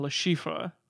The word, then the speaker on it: Le Chiffre: And that made me laugh so hard because nobody in this movie can figure out whether to pronounce the R-E on Le Chiffre. Certain people just go Le Chiffre.